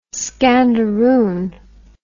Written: scandaroon

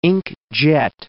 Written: ink-jet

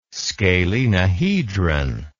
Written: scalenohedron

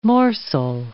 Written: morsel

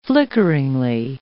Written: flickeringly

